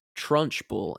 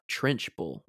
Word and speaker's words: Trunchbull: Um, I was corrected while watching this movie that uh, her name is Trunchbull and not Trenchbull.